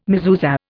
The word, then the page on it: mezuzah: Indian